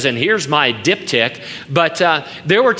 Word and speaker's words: diptych: Well, there was something called a diptych, D-I-P-T-Y-C-H, and it's pronounced diptych.